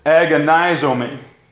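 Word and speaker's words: agonizomai (ἀγωνίζομαι): That word, by the way, is agonizomai. That's perfectly pronounced in the Greek there, maybe.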